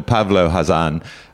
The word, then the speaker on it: Pavlo Khazan: He's given me a bit of a rocket to get the pronunciation right, but I won't get it wrong from now on. Colonel Pavlo Khazan.